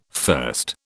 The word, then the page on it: first: British English